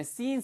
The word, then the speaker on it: seen: 거기에 stolen 대신에 seen 쓰시면 됩니다. He might have seen it. 길게 하셔야 돼요. He might have seen 발음을 길게 하셔야지. 짧게 하시면 죄란 뜻입니다. S-I-N.